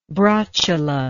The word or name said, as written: brotula